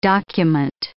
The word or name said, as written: document